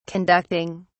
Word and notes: conducting: British English

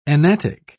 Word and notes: enatic: British English

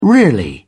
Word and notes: really: British English

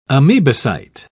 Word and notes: amebocyte: British English

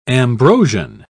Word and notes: ambrosian: British English

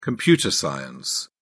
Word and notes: computer science: British English

